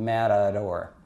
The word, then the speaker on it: matador: It's spelled with a T and a D, but it's not a T and a D. It's actually the sound, matador, if you think about it.